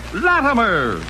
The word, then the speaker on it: Latimer: That name again? – Latimer. – Lammer? – Latimer. – Atomer? – Latimer. – Spell it. – L-A-T-I-M-E-R.